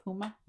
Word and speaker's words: Puma: So Brianna Stewart and Puma, is that how you say it? Puma? – Mm-hmm.